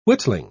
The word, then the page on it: whittling: British English